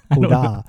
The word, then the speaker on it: Oda: And then do people always say Oda or do they say Uda? – Oda.